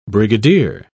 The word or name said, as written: brigadier